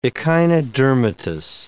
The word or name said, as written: echinodermatous